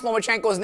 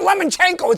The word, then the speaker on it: Lomachenko: Guys who can't pronounce Lomachenko's name should automatically be deducted points. He's not a lemon. It's not Lomachenko. It's Lomachenko. – Hey. – There's no N.